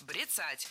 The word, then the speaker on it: бряцать: Значит, они начинают бряцать именно ядерным оружием открыто. – Догадались, какое слово было произнесено неверно? Это слово «бряцать», потому что правильно говорить «брецать». Ударение в этом слове ставим на второй слог.